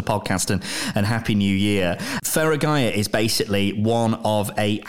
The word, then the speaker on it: Feragaia: 2024 is here, and I should say a very happy new year to Jamie Wild, who's the co-founder of Feragaia.